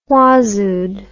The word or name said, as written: whazood